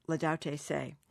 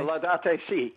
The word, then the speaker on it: Laudate Si': And he said the best thing he ever read about climate change was Pope Francis Laudate Si'. Or C. How do you pronounce that? You're a Catholic school boy. – Laudate Si. – Laudate Si. Thank you very much. – You got it right.